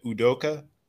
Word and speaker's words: Udoka: Udoka.